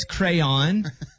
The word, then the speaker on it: crayon: A crown. – I'll accept it. You said crown, but it's crayon.